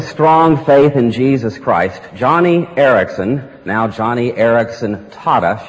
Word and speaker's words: Joni: Johnny started, by the way, Johnny is spelled J-O-N-I. Looks like Joni, but it's pronounced Johnny.